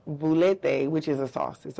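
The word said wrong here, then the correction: velouté: What kind of sauce? – Veloute. Veloute. That's how you say it. Veloute.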